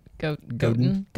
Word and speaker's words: Godin: Yeah, but I thought it was Godin, but you said Goten? You sort of pronounced it. – Godin. – I read The Purple Cow,